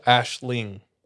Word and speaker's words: Aislinn: L-I-N-G? – Says, well, how she spells it is A-I-S-L-I-N-N. And then she did like a pronunciation thing. And it's A-S-H-L-I-N-G. – Huh. – It's an Irish word for dream.